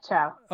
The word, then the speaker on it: Chao: Yeah, Chow.